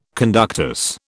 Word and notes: conductus: British English

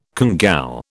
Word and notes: congou: British English

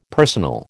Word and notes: personal: American English

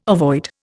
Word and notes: avoid: Indian

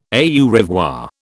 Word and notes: au revoir: British English

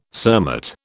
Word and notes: cermet: British English

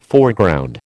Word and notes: Foreground: American English

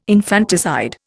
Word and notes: infanticide: Indian

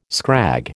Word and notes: scrag: American English

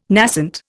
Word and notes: nascent: Indian

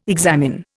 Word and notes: examine: Indian

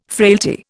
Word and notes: frailty: Indian

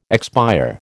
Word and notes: expire: American English

